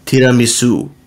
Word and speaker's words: tiramisu: But I prefer to pronounce it the Italian way, and the Italian way is tiramisu.